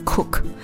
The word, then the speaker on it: CUC: 之前可能有听我聊到，古巴有两种钱，一种叫 CUP，一种叫 CUC，我们发音就是 CUP 跟 Cook，其实这两个真的很相近啊。那所以每次在古巴都要非常明确的去跟对方确认，你跟我报的价是 Cook 还是 Coup？